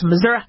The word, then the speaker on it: Missouri: For those of you who don't know what Missouri is, is Missouri, the word's supposed to be pronounced Missouri.